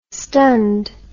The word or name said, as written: stunned